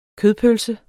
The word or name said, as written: kødpølse